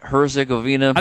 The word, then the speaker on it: Herzegovina: Herzegovina? – I think so.